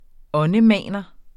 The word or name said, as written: åndemaner